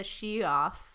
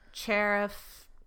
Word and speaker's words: Cheruf: So the creature we're going to talk about today is called the Shioff. Shioff. – We'll go with that. – But it's pronounced, or it's spelled C-H-E-R-U-F. So Sheriff.